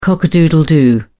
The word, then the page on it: cock a doodle doo: British English